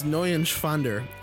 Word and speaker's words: Neuenschwander: Anthony Neuenschwander. – You didn't pronounce it right. Anthony? – Anthony Neuenschwander. – It's N-E-U-E-N. It's Neuenschwander. Anthony Neuenschwander.